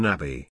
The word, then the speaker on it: Nabi: The featured article for Wednesday, the 29th of August 2018 is Typhoon Nabi. Typhoon Nabi, pronounced Na.Bi, known in the Philippines as Typhoon Jolina, was a powerful typhoon that struck southwestern Japan in September 2005.